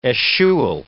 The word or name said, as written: eschewal